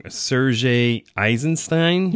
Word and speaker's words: Eisenstein: Yeah, so an example, there's a guy named, I'm going to say his name wrong, Sergei Eisenstein.